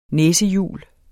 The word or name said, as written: næsehjul